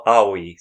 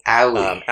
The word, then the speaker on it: Aoi: Well, Aoi. So Aoi. – Aoi is close enough in our English, like way we want to say things.